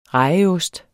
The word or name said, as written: rejeost